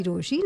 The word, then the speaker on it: Rochie: मेरा नाम है मिर्ची रोशी, नाम की स्पेलिंग है ROCHIE, and clearly I'm terribly offended right now.